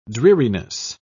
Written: dreariness

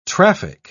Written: traffic

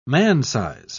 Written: man-size

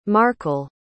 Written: markel

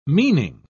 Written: meaning

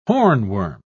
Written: hornworm

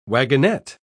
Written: wagonette

